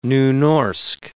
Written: Nynorsk

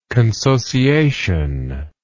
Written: consociationalism

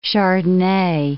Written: chardonnay

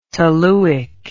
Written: toluic

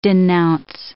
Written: denounce

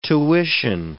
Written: tuition

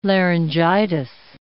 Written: laryngitis

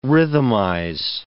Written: rhythmize

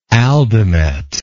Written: aldomet

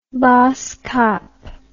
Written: baasskap

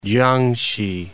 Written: Kiangsi

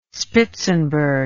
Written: Spitzenburg

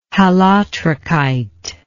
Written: halotrichite

